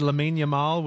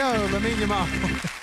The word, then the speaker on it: Lamine Yamal: If I said Lamine Yamal, would that sound right to you? – Yeah, that sounds right. – There we go, Lamine Yamal.